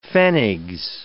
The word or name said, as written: pfennigs